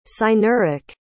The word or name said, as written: cynerik